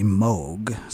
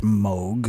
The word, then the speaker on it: Moog: Now, the proper pronunciation of the synthesizer is actually Moog, since it was invented by Robert Moog, who is Dutch, and M-O-O-G is pronounced Moog.